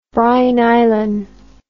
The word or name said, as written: Bri-Nylon